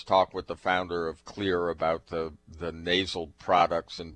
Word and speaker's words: Xlear: And Clear is spelled differently. It's X-L-E-A-R. And that X, it's because where it came from.